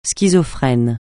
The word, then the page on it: schizophrène: francais